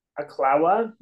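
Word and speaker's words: Ocklawaha: Oklahoma, maybe.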